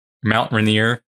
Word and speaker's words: Mount Rainier: That same year, Kenneth Arnold in Washington, Mount Rainier, that's how you say it, also had an encounter.